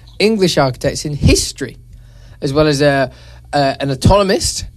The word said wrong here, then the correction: anatomist: or an anatomist, if you pronounce it correctly, an astronomer, a geometer, and a mathematician physicist.